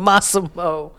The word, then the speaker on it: Massimo: Massimo. – That's how I've heard them pronounce it.